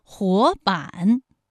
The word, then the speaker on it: 活版: 活版，第一个字是二声，第二个字是三声。